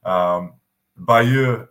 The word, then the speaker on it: Bayeux: Bayeux is the proper pronunciation, but you were very close.